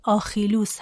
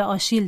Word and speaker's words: آخیلوس: اسم یونانی قهرمان حماسه ایلیاد آخیلوس هست که به آشیل ترجمه شده و اسم نویسنده یونان باستان که ما توی این قسمت ازش صحبت میکنیم آیس خلوس هست که به اشیل ترجمه شده.